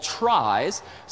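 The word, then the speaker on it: trie: There are these data structures called tries. Silly name derives from the word retrieval, which for some reason is pronounced different from the word tries, but a try is a tree.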